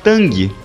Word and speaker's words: Tang: Uma curiosidade sobre o TAN é que se escreve TANG, mas aqui a BID vai abolir a letra G. A BID garante que não é por causa daquele suco em pó que a gente bebia, famoso lá nos anos 80 e 90. Mas sim porque na pronúncia em mandarim não se fala o G mesmo, é TAN.